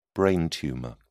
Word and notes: brain tumour: British English

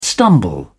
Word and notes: stumble: British English

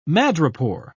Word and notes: madrepore: British English

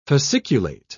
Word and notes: fasciculate: British English

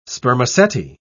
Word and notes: spermaceti: British English

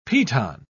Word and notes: piton: British English

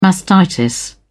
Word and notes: mastitis: British English

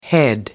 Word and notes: head: American English

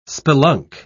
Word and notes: spelunk: British English

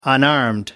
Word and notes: unarmed: American English

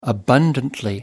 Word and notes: abundantly: British English

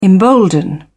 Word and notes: embolden: British English

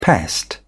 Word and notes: pest: British English

